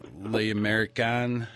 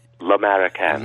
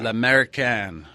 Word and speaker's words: L'American: Well, it's a great story about your father and Le American. How do you pronounce that? Le American or American or? – Pronunciation is L'American. – L'American.